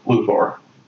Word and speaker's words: Luthor: Which is actually, I think, the way it's supposed to be pronounced, Luthor.